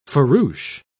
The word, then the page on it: farouche: British English